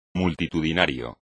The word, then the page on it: multitudinario: espagnol